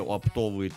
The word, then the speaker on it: оптовые: Едешь, вот в Москве есть большие оптовые торговые центры, покупают... – Правильно говорить, оптовые. – Это у тебя правильно говорить, оптовые. – Ударение на «ы». – Оптовые. Вот так правильно.